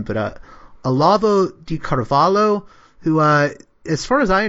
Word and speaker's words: Olavo de Carvalho: Olavo de Carvalho, that was like pretty good pronunciation.